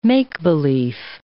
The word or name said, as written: make-belief